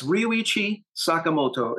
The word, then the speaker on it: Ryuichi Sakamoto: If I'm pronouncing it right, Ryuichi Sakamoto. Am I right? – Yes, Ryuichi Sakamoto.